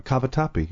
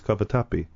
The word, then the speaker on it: cavatappi: He's holding up corkscrews going, cavatappi. You know, in Italian it is cavatappi.